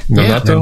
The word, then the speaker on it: Nonato: Am I saying that right? – Nanato? Yeah. Nanato? Yeah, Nanato Music.